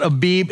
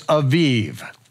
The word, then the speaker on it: Aviv: It's not Elizabeth, it's Elisheva, okay? It's not Abib, it's Aviv.